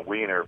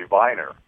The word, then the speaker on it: Weiner: But somehow they don't want to use the EI, and they make it different as a wiener versus whiner, okay? It's really viner because, the W is pronounced as a V.